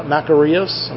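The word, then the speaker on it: makarios (μακάριος): The Greek word, if we were to go back to the Greek text, is translated, get this translation from the Greek word marikaios, or makarios.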